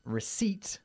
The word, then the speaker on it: receipt: Receipt. – Recibo. – Which, by the way, as you've just heard, has a silent P. We don't say receipt. Receipt.